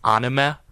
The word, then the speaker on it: anime: Like, an edit with music, I think that really adds towards the lifestyle of certain influencers and cartoons, such as, it's actually pronounced anime.